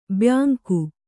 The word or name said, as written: ಬ್ಯಾಂಕು